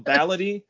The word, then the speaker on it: Baladi: Oh, no, I was going to show you a copy because it's called Ballady, but it's spelled Baladi. Mm-hmm. And that's like the Arabic word for local.